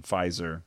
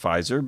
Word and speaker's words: Pfizer: Well, I mean, it makes sense that they would go with Pfizer because it rhymes with Kaiser.